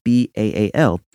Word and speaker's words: Baal: You, you may have heard it as Baal, B A A L. It's pronounced Baal.